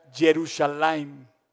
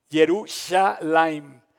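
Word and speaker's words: Jerusalem (ירושלים): Porque en hebreo Jerusalén se pronuncia Jerusalem. Jerusalem.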